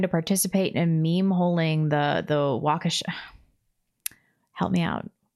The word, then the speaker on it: Waukesha: Just remember walk. Like walk to Waukesha.